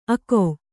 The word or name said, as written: ಅಗ